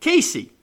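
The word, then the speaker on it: Cayce: You see, John Luther Jones was born in Case, Kentucky. That's C-A-Y-C-E, Case. But like most Southerners, they pronounce it Casey.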